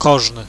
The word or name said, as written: kozny